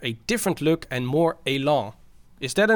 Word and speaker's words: élan: You can use it in English. – Élan. – Élan. Élan. Yeah. Yeah. We don't pronounce it right.